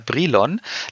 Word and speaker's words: Brilon: Schön nach Brilon zu meiner Großtante. – ja, genau. – Und du hast, es, du hast den Namen der Stadt schon äh, äh, richtig gesagt, nämlich nach Brilon.